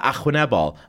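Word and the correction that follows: arwynebol: And I went, no, Arwen Ebal.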